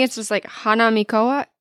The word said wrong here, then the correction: Hanamikoji: Hanamikoji. Hanamikoji, I think is how you say it.